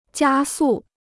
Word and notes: 加速: jiā sù

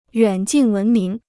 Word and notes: 远近闻名: yuǎn jìn wén míng

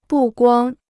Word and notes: 不光: bù guāng

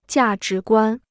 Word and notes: 价值观: jià zhí guān

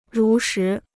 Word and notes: 如实: rú shí

